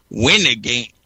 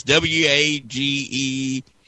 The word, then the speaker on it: Wageningen: A daredevil professor at, I never even heard of this university, Wenegan, I don't even know. Anyway, it's Wageningen, University in the Netherlands. No wonder I can't pronounce it.